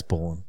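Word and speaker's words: Ballen: It's Ballin. – Anyway. No, he pronounces it Ballin, thank you.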